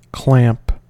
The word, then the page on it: clamp: American English